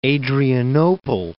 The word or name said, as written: Adrianople